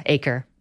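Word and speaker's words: Achor: There's this author called Sean Anker. – Oh, Sean Aker, yeah, from Harvard. – Butcher his name. – Yeah, Aker.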